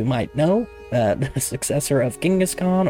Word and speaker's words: Genghis Khan: Also, Genghis Khan pronounced that way because I was told it is closer to how it would have pronounced back then. It's been anglicized into a normal pronunciation.